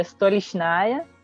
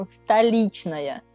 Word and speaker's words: Stolichnaya: A outra palavra que é uma eh, vodka famosíssima, que todo mundo eh, conhece, que é Stolichnaya, ah, que vocês falam aqui, na verdade, uh, em russo ela é pronunciada como Stalitnaya, que é igual o, o, João já falou, que O vira A, então O que não é tônico em russo vira A.